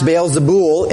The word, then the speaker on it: Beelzebul: Beelzebub, actually pronounced Beelzebul in the Greek. Beelzebub is the Hebrew.